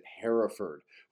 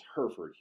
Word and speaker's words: Hereford: With my Canadian upbringing, when I came here at first, I thought you would pronounce it Hereford, which is, you know, a British way of saying the word. But, locally it's known as Hereford here.